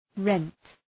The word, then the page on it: rent: {rent}